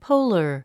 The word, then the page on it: polar: póulə r; ポォラァ